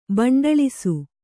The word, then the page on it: ಬಂಡಳಿಸು: Kannada